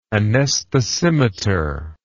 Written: anesthesimeter

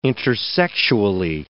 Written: intersexually

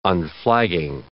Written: unflagging

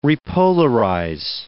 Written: repolarize

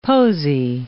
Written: posy

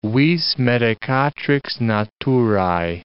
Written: vis medicatrix naturae

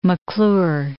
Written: McClure

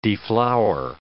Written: deflower

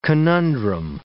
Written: conundrum

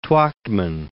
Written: Twachtman

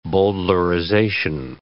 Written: bowdlerization

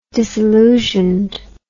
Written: disillusioned

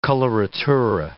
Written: coloratura

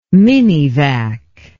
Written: minivac

